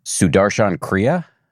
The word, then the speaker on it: Sudarshan Kriya: Sudarshan Kriya, mm-hmm.